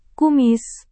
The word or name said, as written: kumis